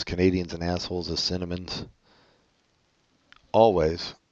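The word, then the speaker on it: Alvvays: Okay, let's listen to Archie Marry Me by Always. Apparently that's how they say it's pronounced.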